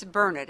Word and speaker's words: Burnett: It's Burnett, and when you're here a while, you'll hear this from folks who live here. It's Burnett, Dern it, Learn it.